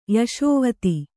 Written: ಯಶೋವತಿ

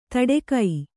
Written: ತಡೆಕೈ

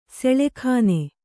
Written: ಸುಲಗ್ನ

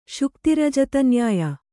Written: ಶುಕ್ತಿರಜತನ್ಯಾಯ

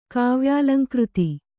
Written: ಕಾವ್ಯಾಲಂಕೃತಿ

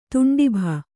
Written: ತುಂಡಿಭ

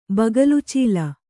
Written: ಬಗಲುಚೀಲ

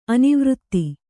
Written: ಅನಾವರ್ತ

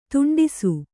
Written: ದಾಂಟಿಸು

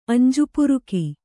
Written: ಅಂಜುಪುರುಕಿ

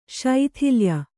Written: ಸುತ್ತಾಲಯ

